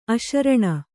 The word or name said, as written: ಅಶರಣ